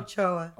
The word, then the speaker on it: Ochoa: Ochoa.